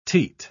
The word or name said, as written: teat